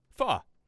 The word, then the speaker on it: pho: So the proper way to pronounce pho is pho.